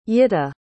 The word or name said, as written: yitta